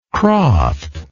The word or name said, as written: croft